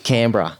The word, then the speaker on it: Canberra: Canberra.